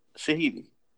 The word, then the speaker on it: Shahidi: Shahidi. I said it right.